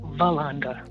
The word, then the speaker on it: Veronda: Hi, my name is Veronda, and I'm calling from Atlanta, Georgia. – Veronda, God bless you this morning. Did I say your name right, Veronda? – No, it's like Yolanda, but it's Veronda.